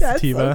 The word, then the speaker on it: sativa: Yes, sativa.